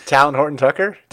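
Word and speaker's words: Talen Horton-Tucker: Me and Taylor and Tuckin Horger. How do you say that guy? – Talon Horton Tucker? – THT.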